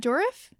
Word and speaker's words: Dourif: And Brad Dourif? – Yep.